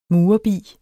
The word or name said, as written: murerbi